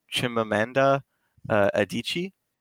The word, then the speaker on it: Chimamanda Adichie: She's a Nigerian writer, and her name is Chimamanda Adichie.